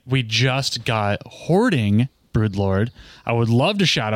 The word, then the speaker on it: Hoarding Broodlord: Hoardling? – Hoardling. Every person says Hoardling. There's no L on this.